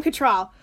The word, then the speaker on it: Cattrall: Kim Cattrall. – So I know her from being in Ice Princess. – Sure. – So what was it like playing the young Kim Cattrall?